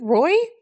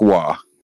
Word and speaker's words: Roy: Isn't it Roy? – No, he's French Canadian. It's pronounced Waugh.